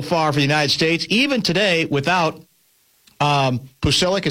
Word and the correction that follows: Pulisic: Is that how you pronounce the name? – Pulisic. – Pulisic. Yeah, I keep from mispronouncing it.